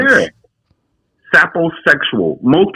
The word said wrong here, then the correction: sapiosexual: Saposexual? Did I say it right? – Yes. Sapiosexual.